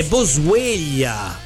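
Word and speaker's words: Boswellia: Non è Bosveglia, è Bosveglia. – E cosa cambia? – Che si scrive diverso. Io pensavo alla sveglia, invece no, è Bo attaccato SW Ellia.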